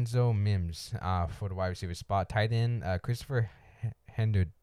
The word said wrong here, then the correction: Herndon: I think that's how you pronounce it. – I can't help you. I got it pulled up. So. – Oh, man. It's Herndon. It's an R. I didn't realize that.